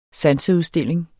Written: sanseudstilling

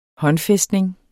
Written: håndfæstning

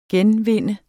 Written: genvinde

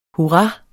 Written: hurra

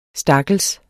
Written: stakkels

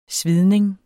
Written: svidning

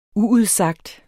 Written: uudsagt

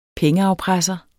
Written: pengeafpresser

